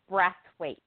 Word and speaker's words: Brathwaite: Brathwaite.